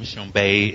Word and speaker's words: Tshombe: Shambay is spelled T like Tom, S like Sam, H-O-M like Mary, B like Bob, E like Ernie, T-S-H-O-M-B-E.